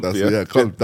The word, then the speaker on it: qalb: Qalb, qalb. That's how you say it. It's qalb right here.